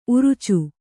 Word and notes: ಊರಿಚ: ūrica

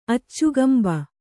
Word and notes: ಅಚ್ಚುಗಂಬ: Kannada

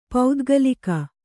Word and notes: ಪೌದ್ಗಲಿಕ: paudgalika

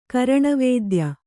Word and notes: ಕರಣವೇದ್ಯ: karaṇavēdya; kannada

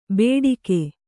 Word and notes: ಬೇಡಿಕೆ: bēḍike

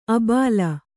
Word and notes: ಆಭೀಲ: kannada

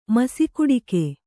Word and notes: ಮಸಿಕುಡಿಕೆ: kannada

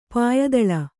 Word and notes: ಪಾಯಿದಳ: Kannada

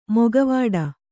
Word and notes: ಮೊಕವಾಡ: kannada

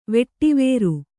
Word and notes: ವಿಡ್ವರ: viḍvara

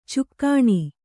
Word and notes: ಚುಕ್ಕಾಣಿ: cukkāṇi

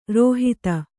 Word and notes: ರೋಹಿತ: rōhita

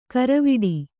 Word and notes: ಕರವಿಡಿ: karaviḍi

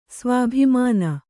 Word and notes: ಸ್ವಾಭಿಮಾನ: svābhimāna

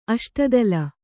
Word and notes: ಅಷ್ಟದಳ: aṣtadaḷa

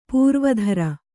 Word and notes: ಪೂರ್ವೋತ್ತರ: Kannada

